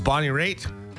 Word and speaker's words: Bonnie Raitt: It's Bonnie Raitt.